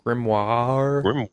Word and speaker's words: grimoire: It's not W-A-H. – Grimoire. Isn't it French? Anna, what? How do you say it? – Don't pronounce the R. Thank you. Carry on. – Apparently Anna can't talk. – Okay. No, you don't. I know enough French to tell you don't pronounce the R. Trust me.